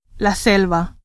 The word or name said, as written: selva